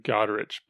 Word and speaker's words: Godrich: So, is it Godrich or Godric? – How do you pronounce his last name? Godrich, but it's kind of like that Sigur Rós, you know, right. – Yeah, it's however you want right. to say it.